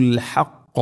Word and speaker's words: الحق: ولا تلبسوا الحق بالباطل ولا تلبسوا الحق ഇവിടെ അൽ ഹഖ ഹഖ എന്നതിലെ ഹാഉം ഖാഫും ഉച്ചാരണം പ്രത്യേകം ശ്രദ്ധിക്കണം. അൽ ഹഖ എന്ന് പറഞ്ഞത് ولا تلبسوا الحق എന്നല്ല ولا تلبسوا الحق ഹാ തൊണ്ടയുടെ മധ്യഭാഗമാണ് അതിൻ്റെ മഖ്റജ് ആ അക്ഷരം വരുന്ന സ്ഥലം.